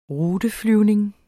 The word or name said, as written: ruteflyvning